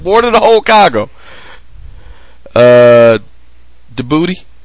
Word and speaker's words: Djibouti: It's Djibouti. – Djibouti. – Because you don't pronounce the D.